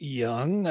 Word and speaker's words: Jung: Josh Jung of the Texas Rangers, last name spelled J U N G, of course, but pronounced Young.